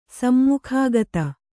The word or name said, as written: ಸಮ್ಮುಖಾಗತ